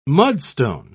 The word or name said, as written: mudstone